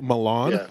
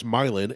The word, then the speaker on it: Milan: We have Milan, which they pronounce Milan and get very mad if you say Milan.